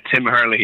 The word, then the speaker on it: Tim Hurley: Yeah, I know Hurley, he has a lot of extra letters, silent vowels in there. So Tim Hurley is how it's pronounced.